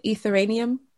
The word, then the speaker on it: Ethereum: is it Ethereum, which is really bad for the, did I say that right? – Yeah, because you have to like mine Bitcoin.